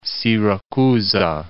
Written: Siracusa

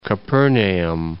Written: Capernaum

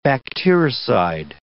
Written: bactericide

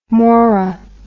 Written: morra

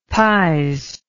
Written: pies

